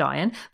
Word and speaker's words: Ian: On the flip side, we have Mythic Quest, which follows a gaming company led by ideas genius Ian, pronounced Iron, played by Rob McElhenney, and developer genius Poppy, played by Charlotte Nigdeo.